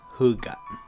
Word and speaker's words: Hygge: It's spelled Hygge, but it's pronounced Hygge.